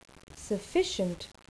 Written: sufficient

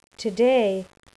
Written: today

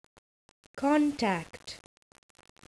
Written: contact

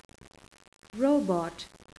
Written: robot

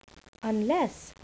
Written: unless

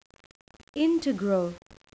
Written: integral

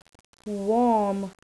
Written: warm

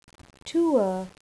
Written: tour

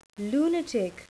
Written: lunatic

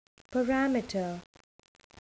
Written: parameter

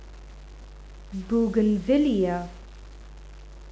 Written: bougainvillea

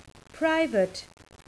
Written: private

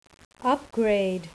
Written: upgrade

